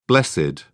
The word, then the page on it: blessed: British English